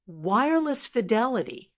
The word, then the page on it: wireless fidelity: American English